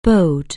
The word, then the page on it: bide: anglais